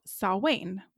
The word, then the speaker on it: Samhain: Okay, so number one, Halloween started as a pre-Christian Celtic festival called Samhain, which is the correct pronunciation, I believe. Correct me if I'm wrong.